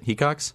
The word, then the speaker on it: Hecox: Ian Hecox on the podcast. That's how you say it, right? Hecox? – You said it right.